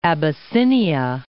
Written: Abyssinia

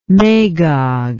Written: magog

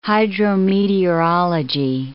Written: hydrometeorology

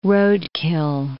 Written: roadkill

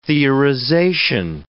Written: theorization

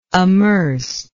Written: amercer

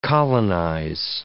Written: colonize

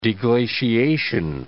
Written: deglaciation

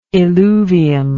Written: eluvium

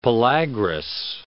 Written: pellagrous

